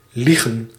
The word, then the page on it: ljuga: /ˈjʉːˌɡa/